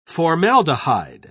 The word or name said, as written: formaldehyde